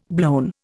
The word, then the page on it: blown: Indian